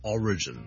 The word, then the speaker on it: origin: number two 咧，如果读成 origin 咧，就会怪怪地啦，因为个重音其实 origin o r i g i n 个来源咧，我哋个重音系第一个音节嘅，所以 single origin。第三件事要留意，如果讀成 origin 咧，除咗我將個重音放咗喺第二個音節之外咧，我哋個 vowel 都唔同咗。我哋唔係 region，譬如我 r e g i o n 一個地區，我哋叫 region 咧，咁咧就係 re，我哋係 e vowel。